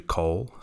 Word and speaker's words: Cole: And of course, my surname is actually Cole, spelt C-O-L-E, and every Indonesian we encountered called me Mr. Cole, because I suppose that's a logical pronunciation if you're going phonetically by English, and that's not your native language.